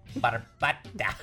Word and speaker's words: Barbata: Barbata. That's how you say it. – You have to roll the R.